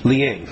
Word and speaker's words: Liang: I had guessed at Leong, but what's the... – Ling. – Liang. – L-I-A-N-G.